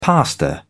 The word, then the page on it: pastor: British English